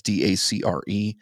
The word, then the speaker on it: Dacre: And so I've got, I don't know how to pronounce his first name. Is it DeCray Montgomery or something like that? It's DaCre Montgomery.